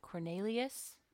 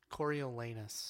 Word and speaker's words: Coriolanus: And how do you say his name? Cornelius? – Coriolanus.